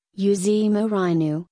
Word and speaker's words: José Mourinho: José Mário dos Santos Mourinho Félix, GOI, Portuguese pronunciation, José Mourinho, born January 26, 1963.